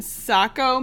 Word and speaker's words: Saco: So this is an IPA, and this is brewed by Barreled Souls in Saco, Maine. – Probably. Yeah. – Is that how? – Yeah. That's how you say it.